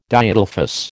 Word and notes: Diadelphous: American English

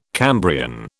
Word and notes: Cambrian: British English

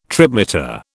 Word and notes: tripmeter: British English

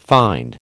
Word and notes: find: American English

